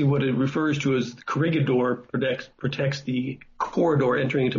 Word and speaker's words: Corregidor: And how would you how do you correctly pronounce the name? I've always said Corregidor. – It's Corregidor. – Corregidor? – It's actually a Spanish word, yeah. The Spanish named the island.